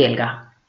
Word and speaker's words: Helga: Soy Elga, Elga con H, y esa es, ha sido y será mi marca personal, porque llevo media vida explicando que mi nombre lleva una H, que aunque no se pronuncia, en Alemania sí, pero que ahí está, muy presente, y esa H forma parte de mi historia.